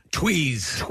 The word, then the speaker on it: Tewes: Tweez. That's what I've heard. – Okay, T-E-W-E-S is how it's spelled. And I would say Tuez, but odd. But Tweez, okay.